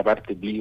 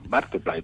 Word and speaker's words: Bartleby: Hay gente que pronuncia Bartleby, no sabemos muy bien cómo, pero no, Bartleby, voy a decir yo, el escribiente.